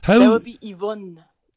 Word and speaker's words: Yvonne: That's how the French would pronounce it. Oui, Yvonne. All right, well, it's better than the Americans. Yvonne. It's like, no, my Yvonne. Name is not supposed to rhyme with yogurt.